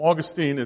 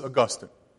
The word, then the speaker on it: Augustine: Well, some people call him Augustine, it's Augustine. That's usually how his name is pronounced.